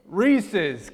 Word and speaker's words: Reese's: Reese's, because their last name is Reese and it's possessive, an apostrophe S.